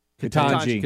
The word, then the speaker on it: Ketanji: Ketanji.